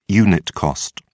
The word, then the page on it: unit cost: British English